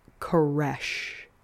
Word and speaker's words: Koresh: David explained that it's like the last exhalation of breath before a person dies. It's Koresh, which I just thought was kind of creepy, so I wanted to put it in there.